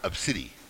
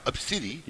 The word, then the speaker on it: ABCDE: Which, by the way, in the past, like, 3 decades, there's been 300 kids named ABCDE, which is pronounced obsidi. – Yep. – What? – Yes, it's pronounced obsidi.